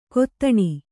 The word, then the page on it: ಕೊತ್ತಣ: Kannada